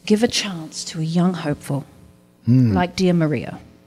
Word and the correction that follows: Mariah: Sorry, Mariah. – Mariah. The proper pronunciation.